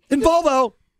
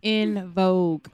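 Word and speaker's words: En Vogue: In Volvo. Or whatever their names are. – In Vogue.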